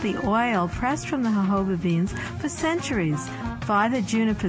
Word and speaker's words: jojoba: Natural Medicine Chest, we'll discuss jojoba, the miracle plant of the desert. – Jojoba is spelled J-O-J-O-B-A, but is pronounced according to its Spanish origins.